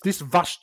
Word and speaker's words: Vush: But how do you spell that? – It's V-U-S-H Ella. It's a Vush Ella and Dom.